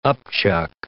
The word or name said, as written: upchuck